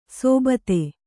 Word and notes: ಸೋಬತೆ: sōbate

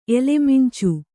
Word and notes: ಎಲೆಮಿಂಚು: elemiñcu

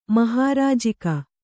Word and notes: ಮಹಾರಾಜಿಕ: Kannada

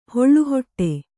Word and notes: ಹಾಳುಹೊಟ್ಟೆ: hāḷu hoṭṭe

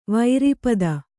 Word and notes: ವಿರೂಪತೆ: virūpate

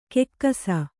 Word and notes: ಕೆಕ್ಕಸ: kekkasa

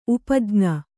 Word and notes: ಉಪಜ್ಞೆ: upajñe; kannada

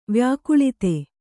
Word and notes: ವ್ಯಾಕುಳಿತ: vyākuḷita

